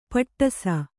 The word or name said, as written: ಪಟ್ಟಿಸ